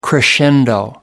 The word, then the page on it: crescendo: American English